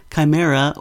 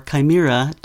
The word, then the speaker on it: chimera: It can be pronounced chimera or chimera depending on your geographical location. That is, whether you're using British or American English.